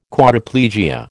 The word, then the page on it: Quadriplegia: American English